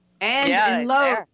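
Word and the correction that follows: Lewes: Lewis. – Lewis. – Lewis. I knew I was pronouncing it wrong.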